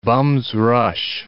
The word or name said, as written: bum's rush